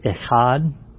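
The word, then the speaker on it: echad (אחד): Because the Hebrew word for one is a word that's echad. You have to say it like you're going to bring something up.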